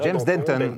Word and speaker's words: James Denton: James Denton. Alors, je ne sais pas comment vous prononcez ça. – James Denton. Vous l'avez bien dit.